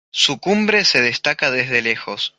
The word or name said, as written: lejos